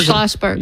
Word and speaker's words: Schlossberg: We have this guy, how do you say, Schlossenberg? – Schlossberg.